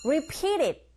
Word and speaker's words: repeated: Repeated가 아니라 네, to 사운드가 강모음, 양모음 사이에 자리잡고 있으니까 repeated 하면서 the 요 받침으로 가게 돼서 repeated, repeated. 첫 글자 발음하실 때 중요하죠. Li가 아니고 우 하면서 re, repeated.